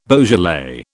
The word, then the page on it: Beaujolais: British English